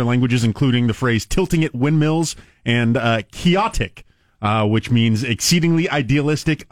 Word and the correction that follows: quixotic: Quixotic. Ah, I said it wrong. – Quixotic.